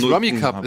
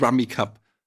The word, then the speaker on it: Rummikub: Nein, warte mal kurz, die Aussprache ist tatsächlich Rummy Cup. Es ist ein englisches Spiel, beziehungsweise... – Natürlich heißt es Rummy Cup.